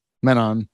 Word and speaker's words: Menon: It goes back to what Alok made Venom. I'm not saying that right. Alok. – Menon. Yeah.